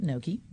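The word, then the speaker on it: NOCHI: So New Orleans Culinary and Hospitality Institute, and we do pronounce it Noki, kind of like a food word, you know.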